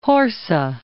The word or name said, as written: Horsa